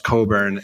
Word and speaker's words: Cockburn: I recently was in a session where I called Bruce Cockburn, and someone very graciously told me that it's pronounced Coburn.